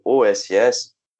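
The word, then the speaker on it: OSS (押忍): O OSU, ou OSS, é, é, você pronuncia né, geralmente com o som do S, o U fica mudo.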